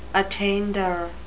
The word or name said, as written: attainder